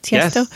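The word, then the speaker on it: Tiësto: Is that how you pronounce Tiesto? – Yes, definitely. – And we actually walked down the aisle, not up the aisle, exited when we, after we got married, we walked down the aisle to Tiesto.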